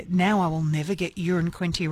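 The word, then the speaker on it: Uranquinty: And I love this because Peter's texted in saying, I live in Urin Quinty. It's the same pronunciation as a number one, so it's not a big town. One could describe it as a wee place.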